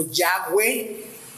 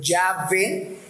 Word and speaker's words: Yahweh: Esto quiere decir que está haciendo referencia al nombre de Dios, ese tetragramatón, que son cuatro consonantes, ¿no? Y, H, W, H, que es algo pues impronunciable para nosotros, y hemos agregado nosotros vocales para poderlo pronunciar, ¿no? Y diría algo así como Yahweh o Yahvé, ¿verdad?